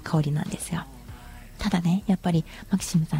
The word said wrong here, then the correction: MAXIM: さんね、マキシンさんですよ。 MAXIM と書いてマキシンと読みますが、